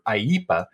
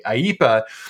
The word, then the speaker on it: IEEPA: Congress has pre-delegated to the president through the International Emergency Economic Powers Act, or IEPA, and we do pronounce it that way. Others don't, but we do because it's more fun that way. IEPA, it works like this.